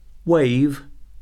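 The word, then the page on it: wave: noun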